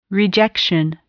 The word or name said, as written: rejection